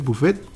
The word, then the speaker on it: buffet: Mi primer jale fue en un lugar de buffet, este, para los de Conalet, buffet es este una palabra en francés.